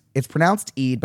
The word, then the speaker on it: Eid: It's pronounced Eid, but it's spelt E-I-D.